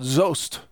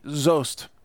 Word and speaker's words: Soest: It's called Zost, or it's spelled S-O-E-S-T, Zost.